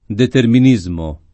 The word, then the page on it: determinismo: lingua italiana